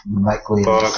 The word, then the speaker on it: maguirei: Magwirei. Puisque c'est en latin, hein, il faut le prononcer en latin.